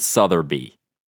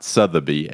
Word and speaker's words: Sotherby: Reginald Sotherby, Look me in the eyes. Thank you for pronouncing the R in Sotheby. Yeah. It's not Sotheby, as everyone tries to say. It's Sotheby. Right.